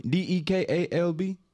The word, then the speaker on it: DeKalb: D e k a l b. That's not. That's not what it is. – DeKalb.